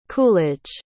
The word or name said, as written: coolidge